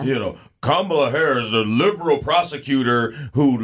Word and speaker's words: Kamala: Just think of Kamala, and then La. – Kamala. – Kamala. – Like Tamala.